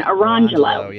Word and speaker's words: Arangelo: The names were spelled lemon jello and orange jello, but they're pronounced Lamangelo and Arangelo.